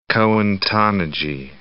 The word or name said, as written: Cohen-Tannoudji